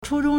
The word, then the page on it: 初中: chūzhōng